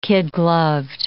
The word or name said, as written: kid-gloved